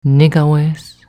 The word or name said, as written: Nigawes